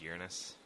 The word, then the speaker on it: Uranus: Uranus is on its side, I'm like fucking laughing coffee out my nose and shit. – It's pronounced Uranus.